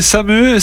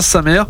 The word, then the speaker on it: Sa Mère: Moi je croyais que c'était Samé, Samé, tout ça. Je voyais pas l'accent, mais ça se prononce bien sa mère.